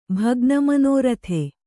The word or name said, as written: ಭಗ್ನಮನೋರಥೆ